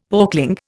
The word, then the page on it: porkling: Indian